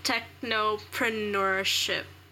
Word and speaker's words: technopreneurship: I can't even pronounce the word. It's such a mouthful. Technopreneurship. Am I saying it correctly? – Mm-hmm. Yeah, you are, actually.